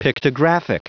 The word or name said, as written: pictographic